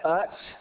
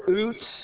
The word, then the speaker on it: Utz: It's spelled UTZ. Is that Utz or Oots? Utz. Okay, I want to make sure I say it right.